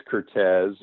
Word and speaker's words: Curtiz: But the correct pronunciation is Curtiz,